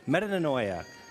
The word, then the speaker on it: metanoia: And so in the New Testament, if you were to look and do a word study for the word repent, the word, I'm going to butcher it, but it's metana, metana yeah. Was that Brad? I, I, was it Brad? Did you yell it out? Someone yelled it out. Metanoia. Metananeo. Okay, there it is. It's on the screen.